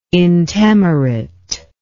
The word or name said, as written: intemerate